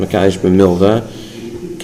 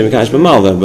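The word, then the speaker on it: Mekadesh b'Milva (מלוה): Also, I won't correct you if you say Mekadesh b'Malva, but it's Mekadesh b'Milva.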